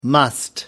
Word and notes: must: American English

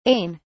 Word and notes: ain: British English

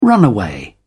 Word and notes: runaway: British English

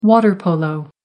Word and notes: water polo: American English

